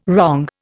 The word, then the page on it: wrong: Indian